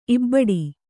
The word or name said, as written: ಇಬಡಿ